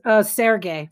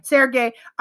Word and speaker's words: Sergei: So we have Tori Spelling, obviously, and how do we say it? Ivan Sergei? – Sergey. – Sergey.